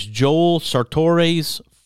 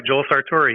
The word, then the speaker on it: Joel Sartore: Now, I may get the name wrong, but it's Joel Sartore's Photo Arc Project. – You got it exactly right, Joel Sartore.